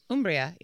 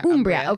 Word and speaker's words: Umbria: Umbria, yeah. – Umbria, okay.